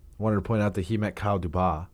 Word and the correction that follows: Dubas: It's Dubas. – Okay. – It's wow. – Kyle Dubas.